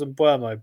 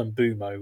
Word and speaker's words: Mbeumo: I've always thought it was Mbeumo, but I now think I saw the pronunciation sheet at Brentford. They put it up on the screen, and I think it was Mbeumo, which just to me sounds like wrong and jarring, but here we are.